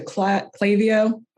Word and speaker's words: Klaviyo: So one of the first things I did this year was migrate our MailChimp to Klaviyo, which I always mispronounce.